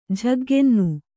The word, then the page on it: ಝಗ್ಗೆನ್ನು: Kannada